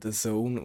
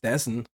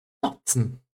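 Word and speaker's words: DAZN: ähm, Wie spricht man das aus? Mir ist mal gesagt worden, das spricht man The Zone aus. Ich, ich habe immer gedacht, das heisst Dessen oder so. – Dazen.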